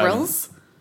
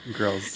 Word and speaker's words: Grylls: Is it Grylls? – Yeah. – Okay. I'm glad I'm saying that right. – Grylls.